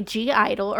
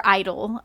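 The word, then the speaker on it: (G)I-DLE: Our next song is Tomboy by G Idol, or Idol, I guess. You don't pronounce the G.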